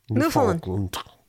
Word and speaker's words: Newfoundland: They always tell me I say it wrong. – Newfoundland. – There we go.